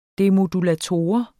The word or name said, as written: demodulator